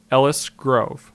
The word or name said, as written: Ellis Grove